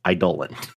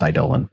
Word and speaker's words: Eidolon: It is pronounced Eidolon. – Yep. Got to look at the root word. And it's definitely supposed to be pronounced Eidolon.